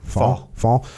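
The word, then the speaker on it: Faul: And finally, you know, as this new CEO came on board, Don, how do you say his last name? – Fall. – Yep.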